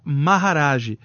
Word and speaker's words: Maharaj: E aí eu fazia esse mantra, que era uma palavra que os sábios usavam, que é o mantra Maharaj. E em todos os mantras hindus se usa antes o Om, que é a vibração do universo. Om Maharaj. Se escreve M-A-H-A, Maha, Iraj, R-A-J, Om Maharaj também, o Om Maharaj.